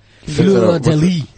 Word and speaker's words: Fleur de Lys: It's the Fleur de Lys, and I'm not pronouncing it right because it's French, but you'll get the idea. Fleur de Lys. Fleur. – Fleur de Lys.